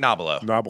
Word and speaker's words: Nobilo: You know, Frank Nobilo, did I pronounce that right? – Frank Nobilo.